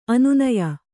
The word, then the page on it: ಅನನ್ಯ: kannada